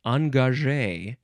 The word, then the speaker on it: engagé: So it is pronounced engagé.